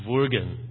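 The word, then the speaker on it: würgen: Interestingly, the English word worry, it traces back to the old High German word, which is pronounced Wurgen.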